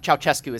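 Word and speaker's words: Ceaușescu: Close enough. Ceausescu is how it's normally pronounced.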